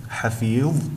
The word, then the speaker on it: حفيظ: ثم في حرف الحاء، حرف الحاء من صفاته الاستفال، في كلمتي حفيظ وحصيد، قد فخمت حرف الحاء في هاتين الكلمتين، والصواب أن تنطقهما هكذا حفيظ بترقيق حرف الحاء وحصيد كذلك.